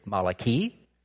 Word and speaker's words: Malachi: If you want to know how his name is pronounced in the Hebrew, it's Malachi.